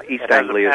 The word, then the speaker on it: East Anglia: East Anglia has a past history of a lot of unusual stuff happening.